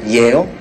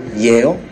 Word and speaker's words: Yale: Yale, then Harvard. Let's go. 練習しましょう。 I read your file, bro. Yale, then Harvard. Let's go. ネイティブに続きましょう。イエールのイエよ。最初の Y を強く発音しましょう。 I read your file, bro. Yale, then Harvard.